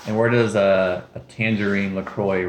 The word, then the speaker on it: LaCroix: On the Wikipedia, it says rhymes with the boys, Lacroix.